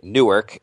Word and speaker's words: Newark: It was a and by the way, that's how New Jerseyans pronounce N-E-W-A-R-K. Most of the rest of the world pronounces it Newark. We just pronounce it Newark.